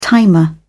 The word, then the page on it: timer: British English